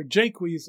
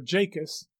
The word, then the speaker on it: Jacques: In As You Like It, there is a character whose name is spelled Jacques, and pronounced either Jaques or Jacus, and sometimes Jakes.